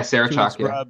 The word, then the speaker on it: Sarah Chalk: Yeah, Sarah Chalk.